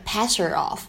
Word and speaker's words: pass her off: 在整句话的朗读过程当中呢，我们注意一下，首先末尾的 pass her off 当中前两个单词呢是可以有击穿爆破的现象的，所以你听起来呢就会感觉我读的是 pass her off。 pass her off 当中这个字母 h 的发音呢，就感觉它没有读出来，这个呢叫做击穿爆破，pass her off。